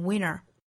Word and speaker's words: winter: 这种情况其实我们之前早读也经常出现啊，比如说 isn't 和 it 的连读读成 isn't it, doesn't 和 it 连读成 doesn't it, 这种情况也出现在单词内部，比如说冬天 winter 读成 winner, 比如说 Santa 读成 Santa 等等。